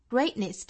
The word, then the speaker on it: Greatness: Greatness 这个单词，我们要注意里面的元音呢是一个 a 的发音，gray, gray。要注意一个元音的滑动，也就是从 a 的口型滑动到一个 i, a, a, 再去加辅音 gray, gray。